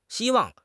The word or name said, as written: xi1 wang4